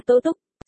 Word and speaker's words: Tố Túc: Nga, sai rồi, là Tố Túc. Celia gian nan, đoán, bất quá, huy, ngươi nói xem đọc có khác nhau sao? Chấm, tì tì, cái chữ kia đọc là Túc, ý là sáng sớm.